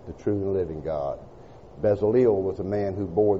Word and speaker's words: Bezaleel: And he always wrote E-L as it were A-I-L. That E-L should be Ale. E-L should be Ale. And then if we look at the phonetic spelling of this man's name, we see that it is Bezaleel. Bezaleel is Bezaleel.